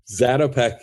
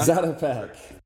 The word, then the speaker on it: Zatopek: Zatopek, is that right? – Zatopek, yeah. – Zatopek. – Very close. I've heard it both ways. It's kind of a tomato situation.